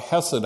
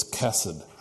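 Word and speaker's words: ḥesed: Show yourself to be somewhat literate about how Hebrew gets transliterated to English, and realize that if there's a dot under the H, that means that it's pronounced as the CH in Bach. So you've got lots of people who will talk about Hesed House, meaning a place of steadfast love and mercy. It's Kesed House.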